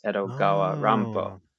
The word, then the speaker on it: Edogawa Rampo: His name is actually, it's Edgar Allan Poe, but pronounced in a Japanese style. So it's Edogawa Rampo.